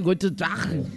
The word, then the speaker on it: Guten Tag: Guten Tag, lo dije bien. – Hola, soy de Alemania y el saludo está muy bien pronunciado. – Ay, muy bien, Guten Tag.